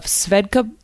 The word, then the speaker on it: Svedka: I'm excited because I'm sitting here right now with a bottle of Svedka vodka. That's how you say it.